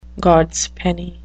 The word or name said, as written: gods penny